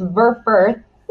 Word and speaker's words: Verfurth: It's a tongue twister. It's really not. It's actually just very German. It's Verfurth.